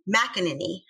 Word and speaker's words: McEnany: Kelly McEnany, I think that's her last name.